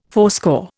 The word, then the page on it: fourscore: Indian